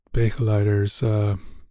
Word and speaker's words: begeleider: And that at that point, I had become their begeleider. How do you say that? – Like begeleiders.